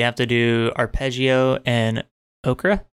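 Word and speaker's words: Ocre: Yeah, I'm pretty sure it's Okra. It's O-C-R-E. – Oh, okay. – All the names are in Spanish, so. But I think Okra's a name.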